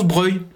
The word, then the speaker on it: Broglie: Ça s'écrit Broglie, mais ça se prononce Breuil. Sauf bien sûr pour les habitants qui snobent cette sophistication et prononcent Broglieplatz.